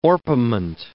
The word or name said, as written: orpiment